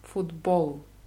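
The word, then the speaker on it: Football (футбол): Wie im Englischen, nur die Betonung ist auf O. Football. – Football. – Ja. Spielen heißt Igrat. Igrat. Ich buchstabiere es. I-G-R-A-T. R-A-T Märchkiznak.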